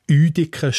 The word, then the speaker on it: Uitikon: Stimmt, man sieht ja auch Uediken statt Uitikon am Jütliberg. Und dort also als weiches T statt das Hörz T.